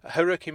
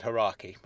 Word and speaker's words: Haruki: Haruki Murakami. I almost said Haraki, but that's not how you say it.